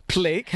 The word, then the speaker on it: Pleic: Pleic.